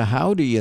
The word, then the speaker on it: Hovvdy: To Howdy, and that's spelled H-O-V-V-D-Y.